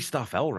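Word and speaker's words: Staffell: So we have a song here written by Brian May and Tim Staffel before Queen. – That would be Staffel, Randy. I'm pretty sure it's Staffel. I think you're pronouncing that with a very Canadian tongue there.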